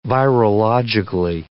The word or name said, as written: virologically